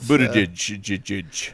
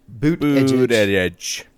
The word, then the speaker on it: Buttigieg: Buttigieg. – It's like the word boot and then edge. – Booted edge.